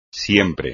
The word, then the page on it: siempre: espagnol